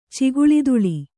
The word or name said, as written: ಚಿಗುಳಿದುಳಿ